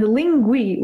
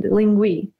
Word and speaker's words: Linguee: So I would recommend Lingui with double E in the end, Lingui.